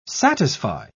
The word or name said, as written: satisfy